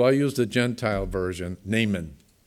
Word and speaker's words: Naaman: I'm going to call him Naaman. That's kind of the Gentile pronunciation. I looked up the Hebrew, and it's Naaman.